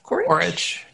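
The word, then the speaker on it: Coric: And so over the weekend, two of the other stars in the tournament, Grigor Dimitrov and I never say his name right, Borna Koric? – Koric, yeah.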